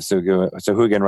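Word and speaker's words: Sahuagin: And now I want to have Suhugan riding sharks and attacking all my ships in the future. – And congratulations on pronouncing it correctly, because it's not the easiest word to say.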